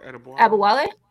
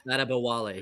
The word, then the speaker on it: Adebayo: Adebwale, did I say that right? – Adebwale. – Adebwale. Yeah. Adebwale. Thank you.